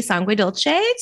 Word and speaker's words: Sanguedolce: Today I have a sweet friend on with me on the podcast, and I'd like to welcome Sophie Sanguedolce to the show.